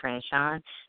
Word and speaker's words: Franchon: My name is Sweet, spelled like the hotel suite, S-U-I-T-E, Franchon, F-R-A-N-C-H-O-N.